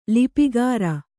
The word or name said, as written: ಲಿಪಿಕರ